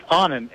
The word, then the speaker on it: Anand: It's Onan, A-N-A-N-D.